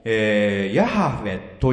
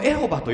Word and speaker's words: ヤハウェ: で、どうもヤハウェというのが正確らしいんですが、これはエホバと読んだ人たちもいるわけね、昔は。ね。で、まあ、英語ではジェホバとなってますね。イヤハウェというのがどうも正確らしいんですけども、この意味は、アイアムです。